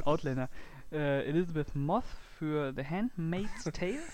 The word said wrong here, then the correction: Elizabeth Moss: Sag das bitte nochmal, den Namen. – Elizabeth Moss.